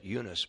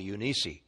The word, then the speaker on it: Eunice: Now in the United States of America, we pronounce that Eunice, but it's literally Eunice in the Greek.